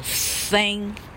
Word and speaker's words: thank: 哈，哎，它是像中文哈仙，啊，仙女散花的仙嘛，哈，仙，哈，为什么？因为它从头到尾没有发到咬舌的音，哈，是念作 Thank。 Thank you, thank you very much, thank you very much, thank you very much。要听到那个气的声音，Thank you very much。